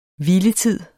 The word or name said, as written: hviletid